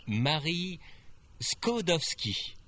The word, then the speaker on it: Marie Skłodowska: My name is Marie Skłodowska. It's rather hard to pronounce. – Marie Skłodowska. How is that for pronunciation? It's a Polish name, right? – Yes.